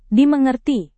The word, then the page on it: dimengerti: Indonesian